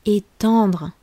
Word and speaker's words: étendre: Ne confondez pas avec le verbe éteindre, qui est très proche au niveau de la prononciation. Éteindre, éteindre la lumière, le contraire d'allumer. Ici, on parle du verbe étendre. Est-ce que vous entendez bien la différence Étendre, j'étends le linge. Éteindre, j'éteins la lumière.